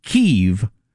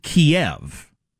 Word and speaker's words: Kyiv: Because Ukrainians pronounce it Kyiv. Russians pronounce it Kiev.